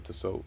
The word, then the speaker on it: Tissot: So the T is silent? – Yep. – Tissot.